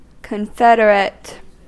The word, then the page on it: ystävä: /ˈys.tæ.væ/